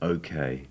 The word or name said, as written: okay